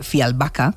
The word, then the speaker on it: Fjällbacka: Pues resulta que los crímenes de Fjällbacka, que es como se pronuncia,